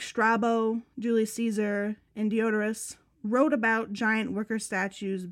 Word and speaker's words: Diodorus: How do you say it? – Deodorous. – That's what it looks like. – I know. I'm sure that's probably how you say it. It just sounds like deodorant. That's it does. I would think it would be like Diodorus. – Diodorus? – Yeah.